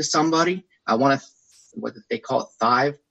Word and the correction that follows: tithe: I always pronounce that wrong. You know, I want to give tithe.